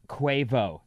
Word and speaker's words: Quavo: Quavo actually is pronounced Quavo. Sarah Quavo. Was right.